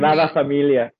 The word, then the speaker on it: Sagrada Familia: the Familia Grada, if I've pronounced that correctly. You're Spanish. Spanish is probably better than me. – Sagrada Familia. – Yes, yeah, that's it.